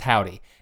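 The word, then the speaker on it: Hovvdy: The Havd, it's H-O-V-V-D-Y. It's pronounced Howdy.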